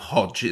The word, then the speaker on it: Hødd: And you're going to have to forgive my pronunciations, but I'm going to go with Hødd. Is that right? – Yes, this is uh, this is the in, in our region in, in our region of uh, of Møre og Romsdal.